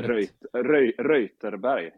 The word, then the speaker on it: Reuterberg: Jag har inte sagt ditt efternamn än, men visst är det Reutenberg. Uttalar det där Reuterberg. – Reuterberg. Ja, det ber jag så mycket om ursäkt.